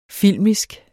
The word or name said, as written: filmisk